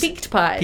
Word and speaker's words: Peaked Pies: Peaked pies? – Peaked pies, peaked yes.